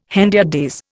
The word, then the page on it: hendiadys: Indian